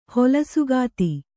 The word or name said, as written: ಹೊಲಸುಗಾತಿ